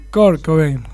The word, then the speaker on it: Kurt Cobain: Tenemos a Kurt Cobain, ¿sí? ¿Lo digo bien? – Kurt Cobain, sí.